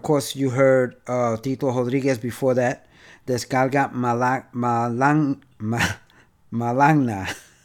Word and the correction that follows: Malanga: Wow, I can't even pronounce it. I'm tongue-tied here. Anyway, Malanga. There you go.